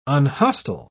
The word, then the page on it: unhostile: British English